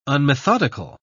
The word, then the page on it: unmethodical: British English